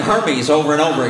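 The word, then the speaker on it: Hermès: Spent money on Hermes. I didn't even know what Hermes was. Luxury bags. – Hermes. – Are they men's? – Hermes. It's pronounced Hermes.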